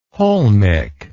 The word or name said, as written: holmic